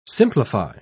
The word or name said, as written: simplify